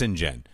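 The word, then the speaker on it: St John: So then I looked it up on Wikipedia and on IMDb, and it is, his brother's name is Saint John Hawk, but it says Saint John, and then in parentheses, pronounced Sinjin. I'm like, no it isn't.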